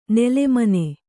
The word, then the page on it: ನಿಲ್ಲುಮನೆ: Kannada